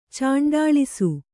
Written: ಚಾಂಡಾಳಿಸು

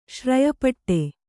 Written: ಶ್ರಯಪಟ್ಟೆ

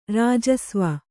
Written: ರಾಜಸ್ವ

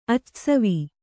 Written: ಅಚ್ಚಸವಿ

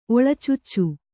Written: ಒಳಚಾಚು

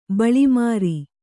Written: ಬಳೆಮುರಿ

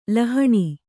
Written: ಲೋಹಿನಿ

